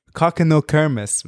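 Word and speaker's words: Kokkinokremos: K-O-K-K-I. – Look, I'm telling you, it's Kakeno Kermos, man.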